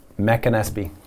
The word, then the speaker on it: McAnespy: It's Mac and Espy.